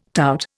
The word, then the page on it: tout: Indian